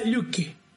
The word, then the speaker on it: Loki: Loki se pronuncia Luki.